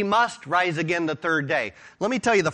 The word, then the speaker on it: dei: He must rise again the third day. He already told you that. And the interesting thing here is that verb must. It's the word, it's transliterated D-E-I, pronounced day.